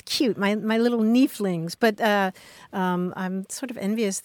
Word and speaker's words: nieflings: There's something about the long E in nieflings that I really like.